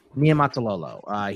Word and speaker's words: Niumatalolo: How do I say his last name? I always mess it up every time. – Niumatalolo.